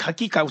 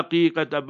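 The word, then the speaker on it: aqeeqa: So remember that haqiqa, not haqiqa. Many times people pronounce it incorrectly. They write with the H. It's wrong that. Haqiqa, with the Ayn, with the A. A-Q-E-E-Q-A. Haqiqa.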